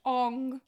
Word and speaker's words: Aang: Especially with our main protagonist, Aang, the Avatar. – It's pronounced Ong.